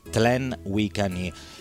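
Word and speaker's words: Tlen Huicani: dove arrivano un gruppo fondato nel 1973 che si dovrebbe pronunciare Wiccani, Tlen Wiccani,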